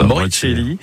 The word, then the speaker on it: Moricelli: Moricelli, c'est pas comment on le prononce. – C'est bien dit comme ça. – Moricelli.